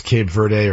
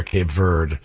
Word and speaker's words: Cape Verde: I'm seeing, by the way, it's pronounced both ways. It's pronounced Cape Verde or Cape Verde, but the Portuguese pronunciation is Cabo Verde.